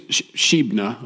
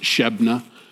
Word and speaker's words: Shebna: His name is Shebna. I think that's how it's pronounced, Shebna. I'm going to choose Shebna because